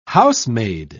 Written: housemaid

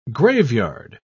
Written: graveyard

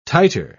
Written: titer

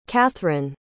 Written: cathryn

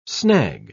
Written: snag

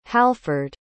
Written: halford

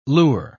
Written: lure

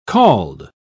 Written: called

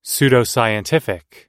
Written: pseudoscientific

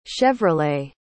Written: chevrolet

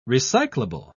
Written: recyclable

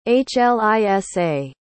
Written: hlisa

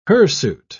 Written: hirsute

